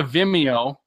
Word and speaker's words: Vimeo: If you go to Vimeo and look up Pursuit, or look up Mike Olbinski, Olbinski, and because you can watch it in 4K.